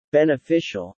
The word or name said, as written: beneficial